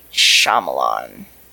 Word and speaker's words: Shyamalan: Night Shyamalan. I said that name wrong, too. – No, you actually got that one right.